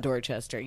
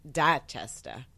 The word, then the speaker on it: Dorchester: And my response to that is, it's not Dorchester. You don't pronounce it like that. It's Dachester.